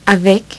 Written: avec

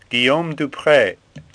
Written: Dupre

